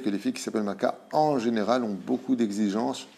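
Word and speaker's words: Malka (מלכה): Et on passe maintenant au nom de Malka. Malka qui veut dire en hébreu reine, mais qui se prononce aussi Milka, qui s'écrit exactement de la même façon.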